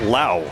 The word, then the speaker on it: Lowe: As Lane said earlier, about an hour and 20 minutes ago, his last name is not spelled the way you'd think. L-O-W-E, but pronounced Lau.